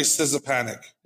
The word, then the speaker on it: Szczepanik: Valerie Szczepanik. It's a nice... – It's Valerie Szczepanik. – Is that right? S-Z-C-Z-E-P-A-N-I-K. I'd like to buy a vowel, please. – It's probably Szczepanik.